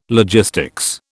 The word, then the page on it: logistics: British English